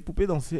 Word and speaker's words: Poupée danser: Poupée danser. Est-ce que je prononce bien Poupée danser Oui, très bien.